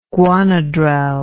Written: guanadrel

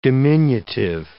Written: diminutive

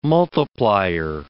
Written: multiplier

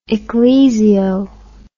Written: ecclesio

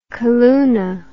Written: calluna

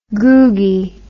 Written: googie

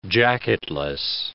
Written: jacketless

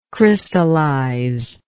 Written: crystallizer

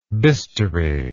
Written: bistoury

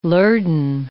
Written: lurdane

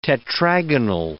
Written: tetragonal

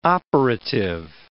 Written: operative

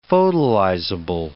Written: photolyzable

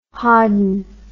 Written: hodden

